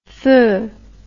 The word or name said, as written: feu